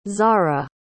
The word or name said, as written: zara